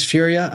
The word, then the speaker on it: Furia: This is Dennis Furia.